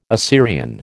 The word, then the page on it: Assyrian: American English